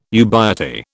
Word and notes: ubiety: British English

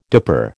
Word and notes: dipper: American English

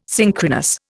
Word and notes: synchronous: Indian

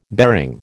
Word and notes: bearing: American English

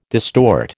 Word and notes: distort: American English

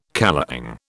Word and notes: kalong: British English

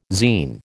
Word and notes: zein: American English

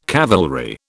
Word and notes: cavalry: British English

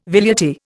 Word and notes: velleity: Indian

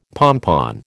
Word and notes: pompon: American English

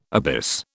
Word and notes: abyss: British English

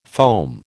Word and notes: foam: American English

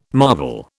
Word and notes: marvel: British English

